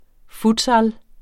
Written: futsal

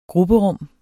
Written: grupperum

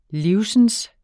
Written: livsens

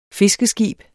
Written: fiskeskib

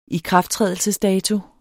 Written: ikrafttrædelsesdato

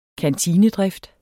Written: kantinedrift

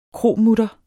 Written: kromutter

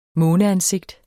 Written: måneansigt

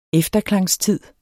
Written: efterklangstid

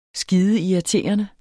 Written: skideirriterende

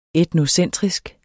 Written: etnocentrisk

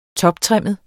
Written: toptrimmet

